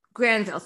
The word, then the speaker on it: Granville: And one of my questions was about the fact that it does take place in Greenville, New York, right? Granville, thank you. I knew I was mispronouncing it.